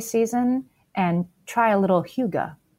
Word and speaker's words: hygge: So hygge, I didn't know how to pronounce it until I looked it up for you guys. It's that word you sometimes see on Pinterest, H-Y-G-G-E.